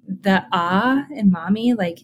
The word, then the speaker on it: Mahmee: I just realized saying it, it's spelled M-A-H-M-E, but it sounds like Mahmee, like M-O-M-M-Y.